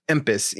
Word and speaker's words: Empis: Empis, E-M-P-I-S, Empis.